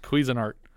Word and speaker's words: Cuisinart: We looked it up. – No. – Yes. I Googled it. I played a YouTube video for you. – I wasn't listening. – It's pronounced Cuisinart.